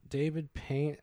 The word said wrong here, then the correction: Paymer: I mean, David Paymer.